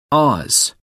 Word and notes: oz.: American English

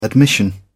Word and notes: admission: British English

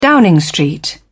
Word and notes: Downing Street: American English

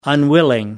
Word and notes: unwilling: American English

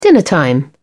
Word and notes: dinner time: British English

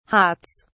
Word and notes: hots: British English

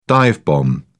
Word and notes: dive-bomb: British English

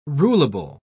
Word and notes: rulable: British English

